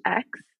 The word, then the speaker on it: Aix: Is it pronounced X, the rosé? – Oh, like the AIX? – Yeah. – I don't think that was me, but yeah, the ex-rosé is good. Ex de Provence.